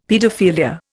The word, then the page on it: paedophilia: Indian